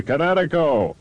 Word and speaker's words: Kinetico: Oh, I mean, East Coast, son, you should have remembered kinetic as in kinetic energy with an O on the end. Kinetico. – Kinetico. Spell it K-I-N-E-T-I-C-O.